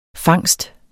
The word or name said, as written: fangst